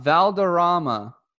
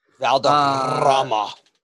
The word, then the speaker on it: Valderrama: The last place he paid for greens fees was out Valderrama, and that's not how you say it because you roll the R's. – Valderrama.